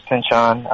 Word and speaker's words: Pynchon: A couple months back, I was just really getting into Thomas Pynchon. I don't know how to pronounce his last name correctly. – Yeah, that's how you pronounce it.